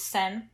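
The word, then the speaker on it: Sen (千): And spoiler alert for later, Chi can be, the Chi in Chihiro can also be pronounced as Sen, and that's where Sen comes from, which also still means a thousand, but it's a less beautiful way of saying thousands, apparently.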